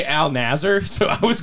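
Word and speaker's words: Al Nassr: It's like... like El Nasir or something, or along those lines. – I was going to say, I was going to butcher it too, so I didn't know if that's what it was. – I'll give it to you. It's close enough. It's Al Nasir FC.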